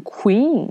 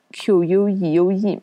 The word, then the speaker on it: queue: 第三个词呢，排队，q u e u e，有的学生可能看的不仔细，就会读成 queen，因为它和那个字也很像。还有的人呢，会读成 q q，因为看到。 q u e u e 嘛，对吧？但其实这个词呢，也是一个单音节的词，它只有一个音节。这个词的正确的读音呢，叫做 q。